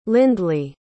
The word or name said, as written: lindly